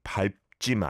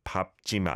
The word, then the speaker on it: 밟지: And the reason for this is 밟 is spelt with a 리을 비읍 받침, right? Bottom consonant, a double syllable one. And the 리을 비읍, when followed by another consonant, the 리을 is silent and the 비읍 sound takes over. So it should be 밟지 마라.